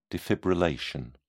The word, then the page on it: defibrillation: British English